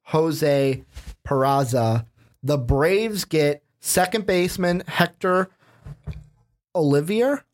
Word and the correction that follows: Olivera: Am I saying that right? – Olivera.